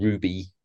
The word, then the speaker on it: RWBY: A series called Ruby, which is spelled R-W-B-Y, but pronounced Ruby.